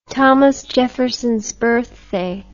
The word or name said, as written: thomas jeffersons birthday